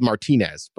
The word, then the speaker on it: Martinez: We're here in Martinez, which is spelled Martinez, but they call it Martinez out here.